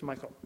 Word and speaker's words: Michal: And I'm going to say Michal. Is that right? Michal? Michal? Michal. Okay, it's Michal.